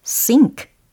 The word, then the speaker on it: sync: 영어에서 이렇게 S, Y, N, C 이 철자를 기억해 두시면 되겠습니다. 이게 바로 sink, sink, sink 라고 발음이 되는 거고요.